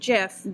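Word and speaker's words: GIF: But really, Abby pronounces it the correct way. She pronounces it Jif.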